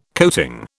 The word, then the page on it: coating: British English